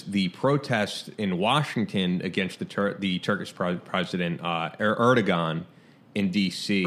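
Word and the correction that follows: Erdoğan: Erdogan. Silent G. – Okay. I'm pronouncing that incorrectly.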